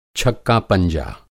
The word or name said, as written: छक्का-पंजा